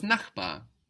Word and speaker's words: Nachbar: The word for neighbor was Nachbar. Der Nachbar. This is spelled N-A-C-H-B-A-R. It ends in a consonant. It doesn't sound like a consonant, though. Sounds like an A, no? Nachbar.